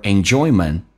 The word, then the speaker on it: enjoyment: 那就挡住遮住了 your enjoyment of the present, 挡住了你目前的人生的一些 enjoyment 的快乐。enjoyment 跟 of 叫 enjoyment of, 记住 oi 这个发音要发的正确一点，不是 for，不是不要发成 oi 就行。